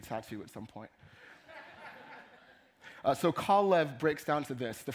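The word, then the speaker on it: Kalev (כָּלֵב): But in Hebrew, the word Caleb is actually pronounced Kalev. It's a combination of two words, Kal and Lev, right?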